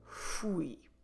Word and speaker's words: fui: I said, eu fui. Como se escreve fui? F-U-I.